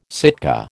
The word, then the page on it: Sitka: American English